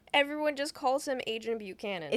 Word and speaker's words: Buchanan: I'm gonna butcher this. Oh, I remember how you say it. I was gonna say Buchanan. It's Buchanan.